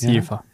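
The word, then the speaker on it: Jever: Aber es ist Jäfer.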